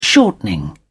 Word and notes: shortening: British English

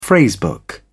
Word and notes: phrase book: British English

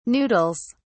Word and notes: noodles: British English

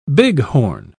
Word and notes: bighorn: British English

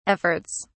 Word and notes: efforts: British English

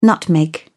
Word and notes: nutmeg: British English

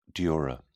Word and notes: dura: British English

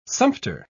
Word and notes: sumpter: British English

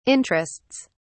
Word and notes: interests: British English

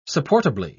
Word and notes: supportably: British English

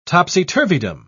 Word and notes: topsy-turvydom: British English

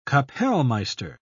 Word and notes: kapellmeister: British English

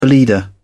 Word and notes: bleeder: British English

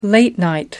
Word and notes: late night: American English

